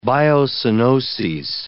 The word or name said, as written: biocoenoses